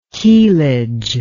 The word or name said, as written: keelage